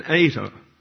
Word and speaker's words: eta: The H is the second letter in the Greek word for Jesus called an eta, and it's pronounced like a long English A.